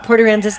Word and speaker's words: Port Aransas: Is that how you say it? – Yep. Port Aransas, Texas.